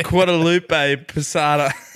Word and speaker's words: Guadalupe Posada: La Katrina was originally a zinc etching created in 1910 by Mexican printmaker Jose Guacamole Posada. – Fucking Jesus. mate, yeah, you keep going. I reckon your pronunciation is probably better than what's written there. – Guadalupe Posada.